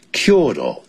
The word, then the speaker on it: Kyoto: Kyoto, 这是日本人的发音。如果是美国人呢，我加了 Kyoto, 这样念 Kyoto, 啊，这样日本人的发音。